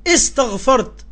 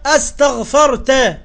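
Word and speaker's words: أستغفرت: وكمان قم الزكريا انت نطقتها استغفرت بكسر الهمز. – استغفرت لهم استغفرت لهم. – ويا قمص يا جاهل يا اللي ما بتعرفش تقرأ الهمزة مفتوحة اسمها استغفرت.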